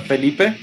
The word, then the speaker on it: Felipe: Now, am I pronouncing your name right? Ah, uh, it's uh, Felipe Machado.